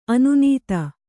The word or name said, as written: ಅನ್ನದ